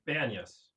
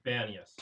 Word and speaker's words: Banyas: Banyas. – I'm sorry? – Banyas. – Banyas. Say that one more time. – Banyas.